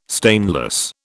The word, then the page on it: stainless: British English